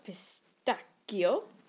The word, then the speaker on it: pistacchio: So, my favourites are pistacchio. Apparently that's how you say it, if you're a proper Italian person, which I'm not, obviously.